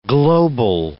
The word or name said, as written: global